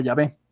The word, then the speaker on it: Yahvé: Les mencionaba que el nombre oculto de Dios se escribe YHWH, serían las consonantes de nuestro alfabeto actual. Eh, recuerden que no había vocales y pues se pronuncia Yahvé.